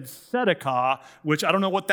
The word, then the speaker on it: tzedakah: So the Hebrew word for righteousness is tzedakah. The emphasis is on the first syllable, tzedakah.